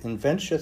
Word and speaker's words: Inventia: Inventia sounds like dementia, only it's a condition where you can't stop inventing.